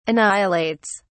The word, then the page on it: annihilates: British English